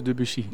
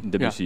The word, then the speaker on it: Debussy: Debussy, hoe moet ik het uitspreken? – Claude Debussy. – Debussy, ja. Nou, zat ik in de buurt.